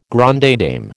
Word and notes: grande dame: American English

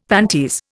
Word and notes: panties: Indian

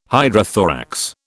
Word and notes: hydrothorax: British English

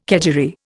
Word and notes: kedgeree: Indian

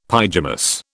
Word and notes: pyjamas: British English

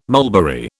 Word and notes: mulberry: British English

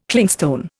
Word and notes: Clingstone: Indian

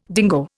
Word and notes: dingo: Indian

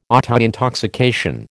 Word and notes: autointoxication: American English

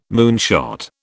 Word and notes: moonshot: British English

